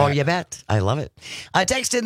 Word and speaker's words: Ubet: There's a place in Wisconsin called You Bet. Oh, You Bet. I'm from You Bet. Is that how it's spelled? – U-B-E-T.